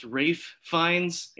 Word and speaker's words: Ralph Fiennes: And it came out in 2002, which would be 12 years after Pretty Woman, starring Jennifer Lopez, Rafe, I believe it's pronounced Rafe Fiennes, and the erstwhile Natasha Richardson.